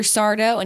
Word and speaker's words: Sardo: But Sardo also had his catchphrase, and his whole deal was that no one ever could, like, pronounce his name correctly. – Yes. – And, like, people would always call him Mr. Sardo, and he would go, I'm Sardo. Nope. No, mister, accent on the dough.